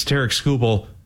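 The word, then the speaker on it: Tarik Skubal: And by the way, tomorrow, it's Tarek Skubal bobblehead night.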